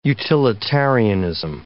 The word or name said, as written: utilitarianism